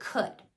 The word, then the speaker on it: could: So, let's start with could. K, uh, d. Three sounds, so they would write three lines on their board. Then we would go sound by sound. The first sound, k, it's spelled with C. That's totally phonetically regular. The second sound, uh, was spelled with O U L. So what I would say to them is it sounds like uh, it's spelled O U L.